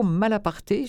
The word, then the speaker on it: Malaparte: J'ai envie de vous proposer Le bal au Kremlin de Curzio Malaparte, j'imagine qu'on prononce bien comme ça, ça s'écrit comme ça se prononce, M-A-L-A-P-A-R-T-E. Nous sommes à Moscou en 1930, la révolution qui s'embourgeoise, la haute société communiste s'amuse avant le bain de sang.